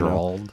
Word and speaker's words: Geralt: I don't Geralt.